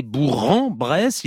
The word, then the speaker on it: Bourg-en-Bresse: il faut bien prononcer Bourg-en-Bresse, il poursuit, c'est une déformation locale patoisante qui fait persister Bourg.